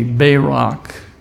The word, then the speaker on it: barak: The Hebrew word for blessed, if you look at the definition in Strong's 1288, it's spelled B-A-W-R-A-K, Barak, and it means to kneel.